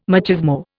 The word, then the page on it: machismo: Indian